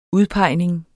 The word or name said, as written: udpegning